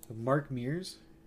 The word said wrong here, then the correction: Mark Meer: I'll give it to you. No S. – Mark Meer. – Mark Meer.